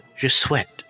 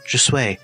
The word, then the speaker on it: Je Souhaite: But, in fact, it should be called Je Souhaite. But he pronounced it Je Souhaite because he's wrong about it.